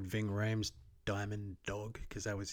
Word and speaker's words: Ving Rhames: Is that how you say his name? – Yeah, Ving Rhames.